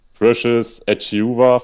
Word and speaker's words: Precious Achiuwa: Precious Achiuwa von den Heat oder so ähnlich. – Mm, sollte richtig ausgesprochen sein.